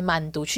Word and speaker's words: Mandu: 这个英文词，所以呢，他们就用韩国的这个发音慢读去拼这个产品，就是 M A N D U 吧。